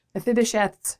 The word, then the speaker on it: Mephibosheth: I guess one of the things I was thinking about is just putting myself in Mephibosheth. Am I saying that right? Yeah. Mephibosheth's shoes.